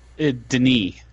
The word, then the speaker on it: Denis: It's not Denis Villeneuve. – Denis. – Denis? – He's French-Canadian.